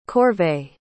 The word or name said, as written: corvée